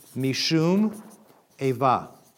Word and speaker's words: Mishum Eva: A lot of them have this thing called Mishum Eva, long A.